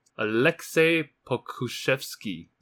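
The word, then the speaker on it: Aleksej Pokuševski: 灰狼在中间第 17 选的那个 Alexei Pokushevsky， is that right？ – 嗯，哦，哎，That's pretty good actually。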